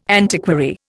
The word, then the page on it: antiquary: Indian